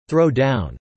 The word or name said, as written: throw-down